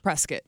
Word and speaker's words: Prescott: Prescott.